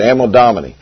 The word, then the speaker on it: Anno Domini: Am I saying that right? I don't know. Okay, that's close enough. A.D. People, it's not after death. Domini, thank you, that's it, Anno Domini.